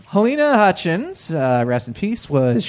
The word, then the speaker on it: Halyna: Unfortunately, allegedly accidentally shot and killed cinematographer Helena, I guess, but it's spelled H-A-L-Y-N-A.